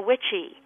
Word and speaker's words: wicce: A man was called a witcha, spelled W-I-C-C-A, pronounced witcha in Old English. And a woman was a witchy, W-I-C-C-E.